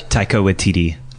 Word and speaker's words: Taika Waititi: And is it Taiki Waititi? – I don't know how to pronounce that guy's name. I panic when I see it. – Taika Waititi.